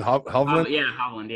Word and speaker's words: Hovland: You look at someone like, I think Victor, and I'm going to pronounce his bad, is it Hovland? – Hovland, yeah.